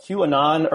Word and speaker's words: QAnon: and it's called QAnon, or is that how you pronounce it? – Yeah. Yeah.